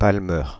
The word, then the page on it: palmer: /pal.mɛʁ/; /pal.me/; France (Île-de-France)